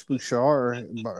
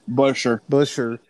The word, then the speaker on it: Buescher: Chris Buescher, I think I said that wrong again. – Buescher has really done well.